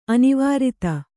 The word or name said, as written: ಅನಾವರ್ತ